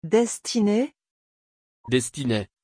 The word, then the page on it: destinay: French